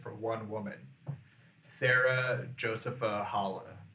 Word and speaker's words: Hale: Halle. H-A-L-E, Hale. – H-A-L-E, Hale.